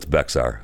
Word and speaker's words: Bexar: But it's spelled with an X, so it's Bexar.